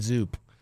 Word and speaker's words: Zoop: Zip, zap, and zoot? – Zoop. – Zoop. – Zoop. – It's spelled Z-double-O-P. – Yeah, it's like hoop with a Z.